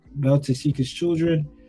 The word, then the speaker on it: Beltorchika's Children: Beltorchika's Children. – Beltorchika's Children.